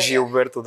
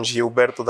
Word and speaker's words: Gilberto: Yeah, it's Portuguese. In English, it's actually Gilberto. – Gilberto. – Yeah. – So with the R, Gilberto. – Yeah, kind of. The I is kind of silent, but people pronounce that.